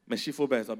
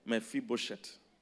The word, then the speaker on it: Mephibosheth: Meshifobes, that guy's name is hard to pronounce. Mephibosheth.